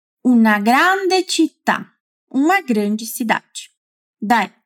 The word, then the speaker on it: grande: Já o contrário, para indicar que algo é grande, olha só que diferente do português. Dizemos grande. Na verdade, é idêntico, né? Só a pronúncia que muda um pouquinho, porque o A é bem aberto.